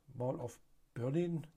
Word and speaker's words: Mall of Berlin: Mall of Berlin, genau so spricht man das ja aus, wenn man schon Mall sagt.